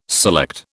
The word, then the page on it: select: British English